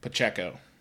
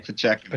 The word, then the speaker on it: Pacheco: Pacheco. – How do you say his name? – Pacheco. – Pacheco.